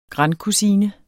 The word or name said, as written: grandkusine